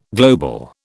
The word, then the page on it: global: British English